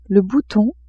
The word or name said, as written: bouton